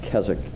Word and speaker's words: Keswick: That's an English from an English revivalistic, annual revivalistic meetings in Keswick, England. Yeah, it is pronounced without the W.